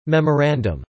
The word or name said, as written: memorandum